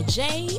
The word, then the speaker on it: Jaie: This is your girl, Jay, and welcome to the J-Spot, and that J is spelled J-A-I-E.